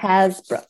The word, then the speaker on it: Hasbrouck: Hasbrouck.